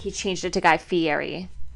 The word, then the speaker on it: Guy Fieri: Guy Fieri? Fieri? – Yeah. – Guy Fieri.